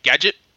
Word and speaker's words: Gdgt: I went to a event for a website called Gadget. That's how it's pronounced. It's spelled G-D-G-T.